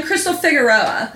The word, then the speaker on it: Crystal Figueroa: Like, immediately, I'm Crystal Figueroa. – Yeah. – Like, it's not that difficult. You don't even have to roll any R's.